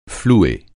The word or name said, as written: flouer